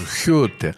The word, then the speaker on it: Гёте: Кстати, надо сказать, что немцы произносят его как «хёте», вот, как и Гейне у них. Хейне, а Гегель у них Хегель.